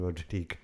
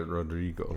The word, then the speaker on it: Rodrigo: Concerto for guitar by the Spanish composer Joaquin Rodrigo. – Rodrigo. Oh, sorry, Rodrigo. Yeah.